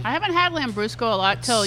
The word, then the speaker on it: Lambrusco: I'll have a million different kinds of red wine and no Lambrusco. – Lambrusco.